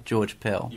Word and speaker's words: George Pell: Okay, well, we'll keep our eyes peeled on the future of Mr. George Pell. I am pronouncing that right, George Pell.